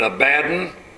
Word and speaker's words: Abaddon: His Hebrew name is, I don't know how you pronounce this, you can call it Abaddon.